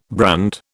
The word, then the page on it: brand: British English